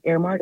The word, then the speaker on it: Heirmark: It's spelled Airmark, like air, like H-E-I-R-M-A-R-K.